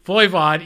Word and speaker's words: Voivod: Voivod, yes.